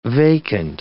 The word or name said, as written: vacant